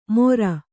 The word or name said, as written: ಮೊರ